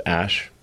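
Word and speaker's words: Æ: I mean, it's just X, the letter X, and then the AE is like pronounced Ash.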